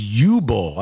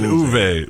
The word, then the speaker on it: Uwe: How do you say his name? I thought it was U-Bull. I think I've said, I'm probably screwed that up forever if that's how you actually say it. – I watched a couple videos and like the Uwe? German pronunciation. Somebody said Uwe.